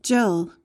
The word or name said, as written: jell